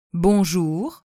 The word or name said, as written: bonjour